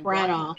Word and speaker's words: Branagh: how do you say his last name? – Branagh. Branagh. – Branagh. – Uh, Branagh.